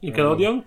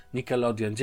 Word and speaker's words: Nickelodeon: E, natomiast w standardowej opłacie za miesiąc mamy 24,99 i w ramach tego mamy produkcję Universal, DreamWorks, Sky, Peacock, Nicole, Nicole ja nigdy nie umiem tego wymówić, Nicole Ledon, nie wiem jak to wymawiać. – Nickelodeon? Prawda. – Nickelodeon. Dziękuję. Showtime, Paramount Plus i Paramount Pictures.